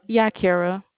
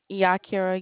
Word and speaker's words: Yakira: Yakira. – Kara? – Yakira, yes, ma'am.